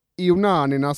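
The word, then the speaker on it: Ioannina: Villagers of Ionanina City. Jag kollade till och med upp hur de uttalade det.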